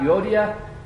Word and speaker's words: Euodia: Now, next week when we begin our discussion, we're going to talk about Euodia and Syntyche. And I do believe that's the correct pronunciation. I looked it up once.